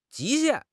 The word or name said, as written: 极限